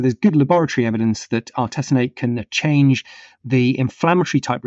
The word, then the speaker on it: artesunate: This first one is the safety and efficacy of artesunate. Is that how you say it? – Artesunate. – Thank you.